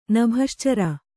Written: ನಭಶ್ಚರ

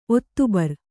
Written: ಒತ್ತಬರ

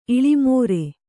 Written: ಇಳಾಮರ

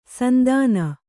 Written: ಸಂತಾನ

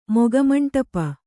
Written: ಮುಖಮಂಡಪ

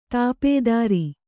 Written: ದಪ್ತರು

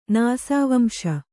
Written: ನಾಸಾವಂಶ

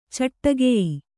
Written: ಚಟ್ಟಗೆಯಿ

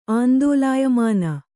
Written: ಆಂದೋಲಾಯಮಾನ

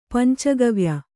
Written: ಪಂಚಗವ್ಯ

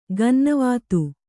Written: ಗನ್ನವಾತು

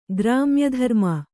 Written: ಗ್ರಾಮ್ಯಧರ್ಮ

